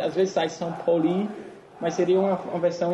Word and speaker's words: Pauli: Não, não, no alemão é, é, é fácil, é se lê como, assim, você, você lê como se escreve, então é, a pronúncia é bem parecida com, com o português, então São Pauli.